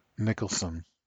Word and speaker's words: Nicholson: Oh, you just said his last name wrong. That's why it's funny. – Oh, say it. – Nicholson. – Yes, thank you. Like Jack Nicholson.